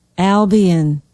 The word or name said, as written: albion